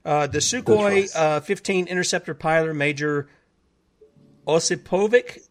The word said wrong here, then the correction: Osipovich: I believe is that maybe how you pronounce it, uh, Osipovich.